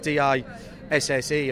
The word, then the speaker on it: DS: In fact, DS, of course, um, DS, the letters D and S, but pronounced DS, D I SSE effectively, uh, meaning goddess in, in French.